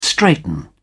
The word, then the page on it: straighten up: British English